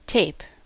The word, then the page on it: tape: [teɪp]; /teɪ̯p/